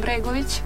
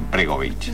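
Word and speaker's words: Bregović: ¿Y Goran Bregovic es así? ¿Cómo se pronuncia? – Sí, sí, sí, Goran Bregovic. – Ajá, Bregovic.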